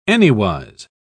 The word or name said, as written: anywise